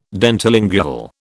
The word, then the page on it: dentilingual: British English